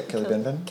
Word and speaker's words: Killibinbin: How would you say that? Killibinbin? – Yeah, Killibinbin.